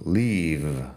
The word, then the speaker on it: leave: En fin, lo primero, en fin, lo primero, leave se pronuncia con la V, v leave. Así es diferente a leaf Leaf sería la hoja de un árbol.